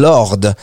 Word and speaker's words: Lorde: super titre que ce titre-là qui s'appelle Royals et le groupe s'appelle Lord. Alors ça s'écrit L-O-R-D-E si jamais vous recherchez sur YouTube.